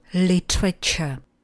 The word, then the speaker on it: literature: A teď tu máme literaturu, kterou v angličtině vyslovujeme jako literature. Jsou to tři slabiky. Li, tr, č.